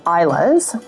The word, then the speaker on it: Islay: I personally am fond of the Islay's, which is spelled I-S-L-A-Y.